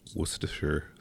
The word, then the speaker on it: Worcestershire: It is Worcestershire sauce. – I still can't pronounce it right. Worcestershire. – So nobody in Worcester says Worcestershire. Now, if you live in the States, you probably would say Worcestershire. But it's Worcester or Worcestershire sauce.